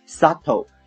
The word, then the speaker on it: subtle: e x e r t 这个单词呢是动词，产生的意思，也许会产生一种 subtle subtle s u b t l e 这里面的 b 不发音，它的意思是形容词，微妙的 influence on children，整个句子就是成年人的行为或许会产生一种微妙的影响在孩子的身上。